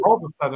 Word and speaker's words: Robison: I'm always sure it's not told. Robertson? – Yeah. – Yeah. Robinson? – Robison. I'm like, no, I know how to say my name. – I know how to say my last name. – Right. – But maybe we don't. – Maybe we don't. Maybe we're wrong. – Maybe we're mispronouncing our own last name.